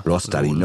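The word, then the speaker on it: Luostarinen: Lustorin, ich kann den Namen nicht aussprechen, Lustarinen. – Ne? – Lostarin, okay. Ryan Nugent-Hopkins, Nikita Kutscherov, Brain Point.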